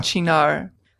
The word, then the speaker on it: Cynar: It's spelled C-Y-N-A-R. And Chinar. – I listened to a pronunciation and it was an Italian woman saying Chinar.